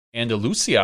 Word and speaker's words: Andalusia: Yeah, the PDGA Euro Tour champion in Andalusia.